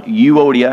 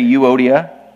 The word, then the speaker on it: Euodia: The first one, Euodia. Everybody say Euodia.